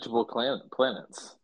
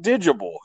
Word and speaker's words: Digable Planets: What's wrong with Digable Planets? – It's not digible. It's not, there's nothing wrong with it, it's just, it's not correct. Digable is not their name, it's the Digable Planets.